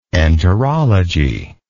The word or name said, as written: enterologist